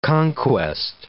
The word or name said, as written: conquest